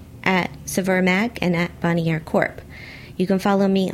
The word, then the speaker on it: Bonnier: Am I saying that right? – Corp. Yeah, Bonnier. – Okay. Bonnier.